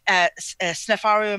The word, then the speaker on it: Sneferu: When you Sneferu, am I saying that correctly? – Yes.